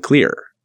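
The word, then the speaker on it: KLR: You can now move them directly to the cross-platform Knative framework using CLEAR, spelled with a K, that's K-L-R, standing for the Knative Lambda Runtime.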